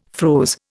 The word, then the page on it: froze: Indian